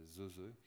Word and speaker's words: Nzenze: Un autre groupe que je conseille très sincèrement à Georges d'écouter, c'est Zeze, ça s'écrit Nzenze, N-Z-E-N-Z.